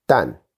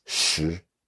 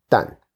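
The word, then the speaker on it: dan (石): Brook at one point measures grain output by the traditional Chinese measure, dan, except he mispronounces it as shi. It's understandable. The character is actually usually pronounced shi, and it's the same character we use to mean stone or rock. But everyone who grew up with a Chinese education knows that when used as a unit of measurement, rather than meaning stone, the character is pronounced as dan.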